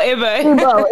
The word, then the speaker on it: Igbo: I was thinking, No, it's pronounced Igbo, correct? – Igbo. – Igbo. – Igbo. – Yes.